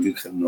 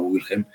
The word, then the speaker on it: Wilhelm: que los alemanes lo pronuncian Wilhelm, no Wilhelm, y es una zona de Berlín que siempre me ha fascinado, ¿no?